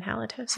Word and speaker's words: halitosis: Halitosis? Have I been saying it wrong? – There's no M in halitosis.